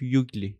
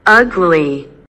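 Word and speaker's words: ugly: من یه معلم زبان داشتم، این آگلی رو میگفت یوگلی. – آگلی. – یوگلی تلفظ میکرد و منم واقعا هیچ راه دیگه ای نداشتم که بفهمم این یوگلی نیست.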